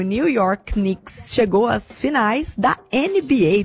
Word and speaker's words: Knicks: Rafa, tô atualizando aqui o nosso feed, a gente volta a falar de esporte, porque Knicks e Seleção Brasileira, eu falei certo? Knicks? – Falou certinho. É assim que pronuncia? Falou certinho, falou certinho.